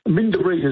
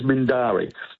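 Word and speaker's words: Mindarie: Yeah, well, yeah, he was Mindaree as Mindari. Let me correct the pronunciation there, which is up near Alkimos.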